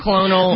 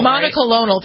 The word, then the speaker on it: monoclonal: Well, the autoclonal, how do we say it? How do you say cucumber? – Monoclonal. – Monoclonal, right. Thank you.